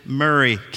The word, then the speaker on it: Maury: It's spelled phonetically Maury, but of course we pronounce it Murray County.